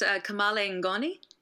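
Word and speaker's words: kamalen ngoni: I hope I pronounced that instrument right, because it is the most fascinating thing I've ever seen. – Thanks. It's Kamale Ngoni.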